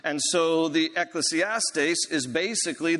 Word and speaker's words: Ecclesiastes: We kind of anglicize that Greek word, and, and more so we pronounce it Ecclesiastes, this book.